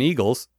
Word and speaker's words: Igel: If you're wondering about Cardassian eagles, it might help that German eagles are hedgehogs, spelled I-G-E-L, but pronounced the same. Eagle.